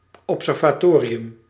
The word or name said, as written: observatory